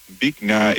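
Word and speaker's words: Bich Nga: How do you pronounce B-I-C-H space N-G-A? – Bick Nga is a Vietnamese name. Bick is pronounced a bit like bick with a short I sound, almost like bit but with a K at the end.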